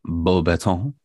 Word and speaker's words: Beauxbatons: I think I'd go to Beauxbatons because Beauxbatons. There you go. That's a much better French pronunciation.